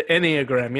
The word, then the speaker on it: Enneagram: Yeah, the Enneagram.